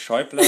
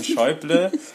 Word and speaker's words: Schäuble: Der ist auch nicht Schäuble, sondern Schäuble. Und er ist aber tatsächlich der Präsident des Bundestages.